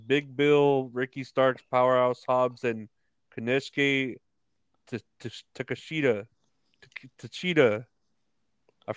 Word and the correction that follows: Takeshita: Uh, Takeshita. That's how you say it. Takeshita.